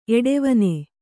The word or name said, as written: ಎಡೆವನೆ